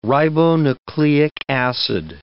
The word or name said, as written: ribonucleic acid